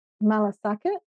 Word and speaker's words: malasakit: So that malasakit? – Yes. – That's how you say it? It's like I can hear that it's a deep connection. It's the empathy, the care. It's you got it.